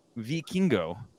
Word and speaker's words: Vikingo: If you say it like an American, it would be how you're saying it, you know, but it's E is how you say I in Spanish. So it's Vikingo.